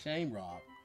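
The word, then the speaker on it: seamróg: And the word is spelled S-E-A-M-R-O-G, but it's pronounced shamrock.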